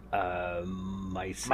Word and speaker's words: Miesse: M-I-E-S-S-E. – I would pronounce it Miese. – Miese, yeah, Mr. Miese. – But yeah, M-I-E-S-S-E, yeah, that's like Germanic in some sense.